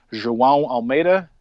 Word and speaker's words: João Almeida: So Ben Hermans was first in GC, then a guy named João Almeida.